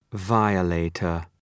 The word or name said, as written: violator